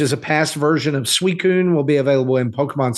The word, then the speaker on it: Suicune: That I knew how to pronounce it? – Okay. Justin wouldn't have gotten it. – Yeah, he wouldn't. He would have called it a Suicune.